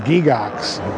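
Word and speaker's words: Gygax: Pronounced in Switzerland, Gigax. It comes from the Greek word gigantus.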